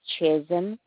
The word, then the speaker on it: Chisholm: T-E-R-E-S-A. – What's your last name? – It's Chisholm. C-H-I-S-H-O-L-M.